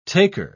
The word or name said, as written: taker